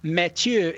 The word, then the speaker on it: Mathieu: But when I was talking with him, he was like, Mathieu is the French pronunciation of it.